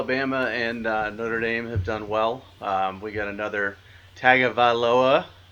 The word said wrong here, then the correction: Tagovailoa: The G is like an NG. – It was never Tonga. – Yeah, Tagovailoa. Like, his dad's name is spelled G-A-L-U, but it's pronounced Nalu.